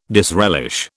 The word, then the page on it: disrelish: British English